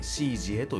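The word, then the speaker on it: 師姐: 師匠の師に姉と書いて「シージエ」と読みますねおそらく中国語読みですよね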